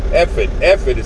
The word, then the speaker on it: ephod: These are the garments they are to make: a breastplate, an ephod, ephod is actually how it's pronounced, ephod, a robe, a woven tunic, a turban, and a sash.